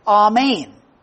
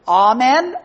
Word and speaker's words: amen: It's pronounced here in the great Greek text as amen. And in English, we pronounce it as amen or amen, depending on what circles you grew up in church life.